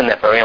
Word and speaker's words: Nephilim: 哈，神的众子随意挑选人的女子作为妻子，生出 Nephilim。 Nephilim 的中文和本是译成伟人，可是你假如看中文的现代译本，翻成什么巨人，翻成巨人。然后呢，英文的 KJV 哦，钦定版哦，翻译成什么 giants。那 NIV 就直接翻成 Nephilim 哦。希伯来文发音也是 Nephilim，我们刚才念过了，它的字根是 Napha，就是堕落的意思哦。